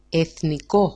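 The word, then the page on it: εθνικό: e8niko-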